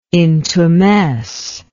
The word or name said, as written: intumesce